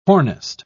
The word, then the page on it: hornist: British English